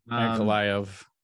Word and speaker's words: Ankalaev: How do you say it? Ankalaev? – Yeah. Ankalaev.